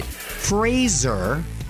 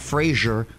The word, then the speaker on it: Fraser: He says he pronounces it Fraser, rhymes with razor, not Fraser.